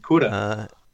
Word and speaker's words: Kura: The next song, and again, you'll have to excuse my pronunciation, but Kura? – Kura.